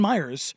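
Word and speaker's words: Miyares: Jason Myers, but it's spelled very differently, sent a demand letter to the NCAA threatening legal action if James Madison was not granted full bowl eligibility waiver.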